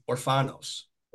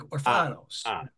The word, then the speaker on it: Orfanos: I'm going to make it real easy. Orfanos. – Orfanos. – Mike. Yeah. So it's like kind of like an like Orfanos. Makes it much easier.